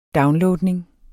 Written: downloadning

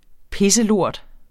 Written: pisselort

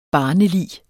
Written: barnelig

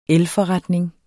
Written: elforretning

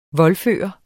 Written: voldføre

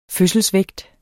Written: fødselsvægt

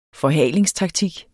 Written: forhalingstaktik

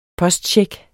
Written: postcheck

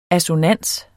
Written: ASSONANSERNE

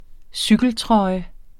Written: cykeltrøje

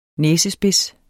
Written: næsespids

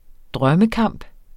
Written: drømmekamp

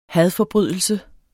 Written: hadforbrydelse

